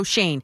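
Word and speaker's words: Schoen: You look at the resume of Joe Shane. That's how you pronounce his name, Joe Shane.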